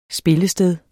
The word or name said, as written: Spillestedet